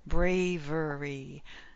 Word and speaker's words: bravery: Bravery. Bravery. Three syllables. Bravery. First syllable stress. Brave. Bravery. Maybe I'm hearing the V move to the second syllable. Braver. But brave should be the longest, strongest sound.